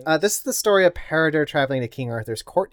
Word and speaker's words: Peredur: Peridur, if I'm pronouncing that right. – Please spell. – P-E-R-E-D-U-R. Peridur.